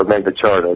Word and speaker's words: Magna Carta: And so this treaty predated the Magna Carta a couple of years later, or Magna Charta, depending on how you pronounce it.